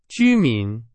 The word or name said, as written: 居民